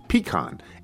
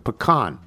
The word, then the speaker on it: pecan: Since the leading producer of the pecan is the southern state of Georgia, the southern pronunciation would be the correct one, and the correct one in the South is, as you and Nigel stated, pecan. Emphasis on the long P, not the short P version of pecan, which is how I pronounced it all my life until last week.